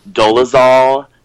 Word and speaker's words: Dolezal: Did you guys see, do you guys remember that whole story about Rachel Dolezal? Is that how you pronounce her name? – Yeah.